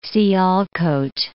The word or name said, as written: Sialkot